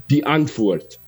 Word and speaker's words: Antwoord: But it's actually, in South Africa, it's Afrikaans, and it means the answer, and it's pronounced the Antwoord, like that.